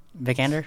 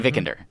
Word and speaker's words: Vikander: If Alicia or anybody from the film is listening, or anybody worked on the film is listening, we're sorry if we butcher her name. – No, you got it right. – Vikander? – Mm-hmm. Vikander. – Okay. – Big fan right here. So Vikander.